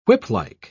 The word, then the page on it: whiplike: British English